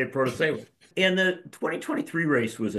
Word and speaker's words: Protasiewicz: We all had to learn how to pronounce the name of the woman who won, Janet Protasiewicz.